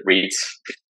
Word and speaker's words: REIT: How do you pronounce that? Rights? – I personally pronounce it REITs.